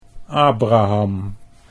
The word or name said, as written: Abraham